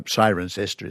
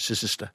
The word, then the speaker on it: Cirencester: Unlike England, if you go to England and, you know, Cirencester is Sicister, and it's not Worcestershire, but Worcestershire and so on.